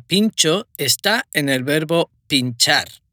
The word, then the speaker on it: pintxo: Se escribe P I N T X O. Pincho, que es la palabra en euskera.